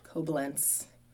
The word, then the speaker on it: Coblentz: Koblentz. – Koblentz. – Yeah, it's German.